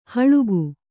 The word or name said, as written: ಹಳಗೆ